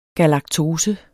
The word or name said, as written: galaktose